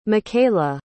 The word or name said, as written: mikaela